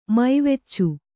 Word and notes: ಮೈವೆಚ್ಚು: maiveccu

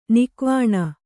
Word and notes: ನಗೆವೆಣ್ಣು: nageveṇṇu

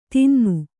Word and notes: ಧೂನನ: Kannada

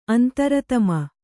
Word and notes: ಅಂತರಾತ್ಮ: Kannada

